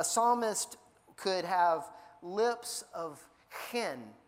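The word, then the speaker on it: chen (חֵן): It's chanun or chen, and you say it like you say hen, but it's got a K on it, so it doesn't really have a K on it, but that's the way we have, like, need to pronounce it, chen, right? You almost have to clear your throat when you say it, chen.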